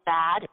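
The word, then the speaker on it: ba (八): because the number 8 is pronounced as ba, and that has a very similar pronunciation to the word fa, which means being rich and being prosperous.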